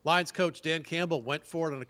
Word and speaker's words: Campbell: Campbell, of course, rhymes with gamble, you know.